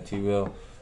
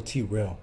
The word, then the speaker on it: Trell: Shit, am I saying it wrong, T-Real or T-Rail? – Second one. – Rail. – Yeah. – Goddamn. – T-R-E-L-L. – T-Rail. Yeah, I got it.